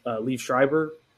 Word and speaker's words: Liev Schreiber: I would say because the main character in Ray Donovan is Liev Schreiber.